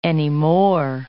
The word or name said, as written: anymore